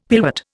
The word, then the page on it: pillwort: Indian